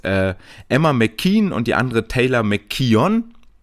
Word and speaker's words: McKeon: Nee, Kaylee McKeon, beide sehr ähnlich geschrieben, Emma McKean mit K-E-O-N und Kaylee McKeon, K-E-O-W-N, auch hier mal schön die wirklich native australische Aussprache zu hören, dann kriegt man auch mal mit, dass das, dass die Emma, die 100 Delfin-Schwimmerin, eher McKean mit Doppel-I quasi gesprochen wird und McKeon noch so ein kleines O mit drin hat.